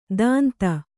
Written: ದಾಂತ

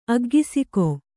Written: ಅಗ್ಗಿಸಿಕೊ

